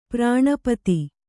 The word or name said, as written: ಪ್ರಾಣಪತಿ